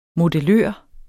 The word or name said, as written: modellør